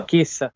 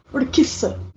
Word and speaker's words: Urquiza: Urquiza. – Urquiza.